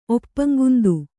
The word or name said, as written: ಒಪ್ಪಂಗುಂದು